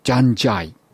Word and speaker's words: DJANDJAY: ベイカーボーイ、昨年出ましたアルバムジャンジャイ、 DJANDJAY と書いてジャンジャイと読みますけれども、このアルバムとそれに先駆けたシングル Six Skin によって今年のニマアワード、ナショナルインディジナスミュージックアワードで見事に 3 冠を達成しました。